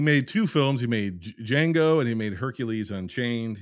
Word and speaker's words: Django: He's like, Django. The D is silent.